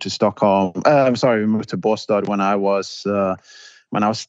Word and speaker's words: Båstad: And Stockholm or Bast, is that how you say it? – Båstad.